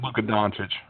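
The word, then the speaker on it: Dončić: Luka Doncic.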